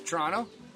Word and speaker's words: Toronto: Yeah, no, it was pretty close. Toronto, sort of a ch kind of thing. – Tr- oh, a little ch. – Okay. Little ch, yeah, good. Toronto.